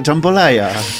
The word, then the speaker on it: jambalaya: Podkarpacka jambalaya. – Tak, i ta jambalaya, bardzo ładnie to wymawiasz, nierozerwalnie wiąże się z, wiesz skąd to jest Louisiana.